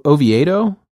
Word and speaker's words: Oviedo: Ovi- o- Oviedo, Oviedo, Oviedo.